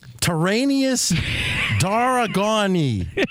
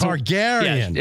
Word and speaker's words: Targaryen: Then we've got this fellow, Tyrannus Daragoni. Is that how you say it? – It's a new dinosaur, actually. It's Tyrion Lannister. – Yes. Yeah. No. It's not Lannister. – Oh, Daenerys Targaryen. – Yes, it's Targaryen.